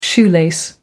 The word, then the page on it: shoelace: American English